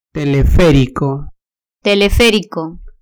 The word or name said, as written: teleferico